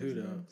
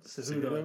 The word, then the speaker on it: Cejudo: But Henry Cejudo, I think that's how you say it. Cejudo. – Cejudo, I think it is, yeah.